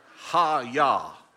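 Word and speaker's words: haya (היה): And that word let in Hebrew, I'm not a Hebrew scholar, but it is the word pronounced haya, not haya. It's haya. That's that word, to let.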